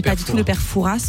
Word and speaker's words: Fouras: Comment vous prononcez son nom à ce monsieur? – Ouais, ça, je crois que je sais. Je ne suis pas sûr, mais je crois qu'on dit le père Fouras. – Exactement.